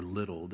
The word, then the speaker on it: Liddell: His name is Eric Liddell, or Little, depending on how you pronounce it. L-I-D-D-E-L-L.